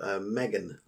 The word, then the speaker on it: M3GAN: I went to see a movie called Megan last night. – Megan, but it's spelled M3GAN because it's not an anagram.